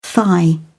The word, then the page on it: thigh: British English